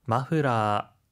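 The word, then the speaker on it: 마프라 (マフラー): 하이, 스카프, 마프라라고 할 때는 F 발음이기 때문에 후 발음이 들어가는데요. 프라고 하지 않도록 주의하시면 좋겠습니다.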